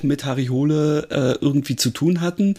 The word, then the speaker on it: Hole: mit seiner Harry Hole oder äh, Hule, glaube ich, wird es ja ausgesprochen, ähm, Reihe ähm, total mag.